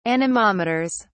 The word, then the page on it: anemometers: British English